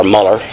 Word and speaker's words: Mueller: One of my heroes of the past to read about is George Mueller, or Muller, some pronounce it,